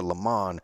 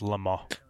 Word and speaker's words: Le Mans: Now we took this to Le Mans and we kicked some butt. – I believe it's pronounced Le Mans.